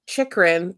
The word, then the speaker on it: Chychrun: And then at that very, almost towards the end of the last sort of like six-ish minutes in the period, Chychrun, is that how we're pronouncing his last name? – Chychrun, thank you.